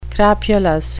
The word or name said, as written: crapulous